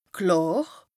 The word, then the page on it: klàr: Bas Rhin; Strasbourg